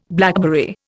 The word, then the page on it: blackberry: Indian